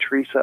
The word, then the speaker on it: Teresa: Now, some people call her Saint Therese, but that's more of a French pronunciation of her name. But in English, of course, we call her Saint Teresa.